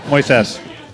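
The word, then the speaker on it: Moisés: Accent on the ses. Moises.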